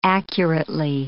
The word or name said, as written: accurately